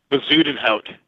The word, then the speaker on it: Bezuidenhout: Bezuidenhout.